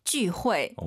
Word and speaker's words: 聚会: 聚会. – 오, 역시 UI 사이에 넣어서 후이 후이 하지 마시고 호에.